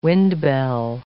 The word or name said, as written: wind-bell